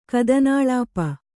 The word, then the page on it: ಕಥನಾಲಾಪ: kathanālāpa